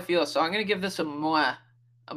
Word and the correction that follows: meh: How do you say it? Moi? Meh? – Meh. – That meh. There we go.